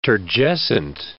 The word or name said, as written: turgescent